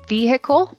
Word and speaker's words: vehicle: Vehicle. – Vehicle 这个词是中间有个 H, 是 vehicle, 这个 H 是不发音的。 – Yeah, we don't say vehicle. – Yeah, we say vehicle.